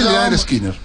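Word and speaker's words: Lynyrd Skynyrd: Se dice Leonard Skinner.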